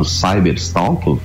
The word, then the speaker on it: cyberstalk: Quando nós migramos para o ambiente da internet, o chamado cyberstalk, falei certo, Álvaro? – Falou tá certo, certo é falou certo.